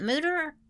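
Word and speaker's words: Mütter: Is it Mooter? Oh, it is Mooter. – Yeah. – Because I was wanting to say something different. – It's spelled M-U-T-T-E-R, yeah. But it's the U with the two dots over top. And yeah. It's Mooter, I think, is what it is.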